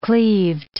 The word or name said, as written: cleaved